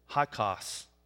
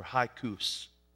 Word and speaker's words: Hyksos: Now, a few years after Joseph dies in 1806, foreign kings ruled Egypt, known as Hyksos or Hyksos, however you want to pronounce it.